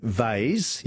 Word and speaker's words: vase: And Robert in Mitcham, David in Adelaide, we say vase, yes, V-A-S-E, rhymes with haze, but we also say Lego for Lego, L-E-G-O.